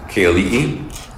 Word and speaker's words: Keali'i: I'm here at Waipa with Uncle K-L-E-E Holden. Uncle, could you please say and spell your name? – K-L-E-E. It's spelled K-E-A-L-I-I.